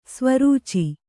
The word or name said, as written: ಸ್ವರೂಚಿ